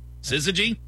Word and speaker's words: syzygy: S-Y-Z-Y-G-Y. Syzygy? – Syzygy. – S-Y-Z-Y-G-Y? – Syzygy.